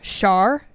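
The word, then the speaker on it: Schär: So it is spelled S-C-H-A with an umlaut R, Char, gluten-free artisan baker white bread.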